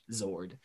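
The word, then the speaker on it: Xord: who is Zen's Zord. That Zord also spelled with an X. That's X-O-R-D, Zord.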